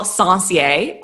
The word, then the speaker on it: Sencier: I am looking forward to having Daniel Sencier on today's episode.